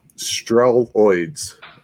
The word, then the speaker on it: Streloids: The first one is called, and I'm going to butcher this, it's hard to say, so I will spell it out too, Streloids. It's like steroids, but it's Streloids.